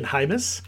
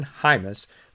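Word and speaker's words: Jaimes: I've been saying James. It's spelled like James. But it is indeed Brendan Hymus from Nebraska.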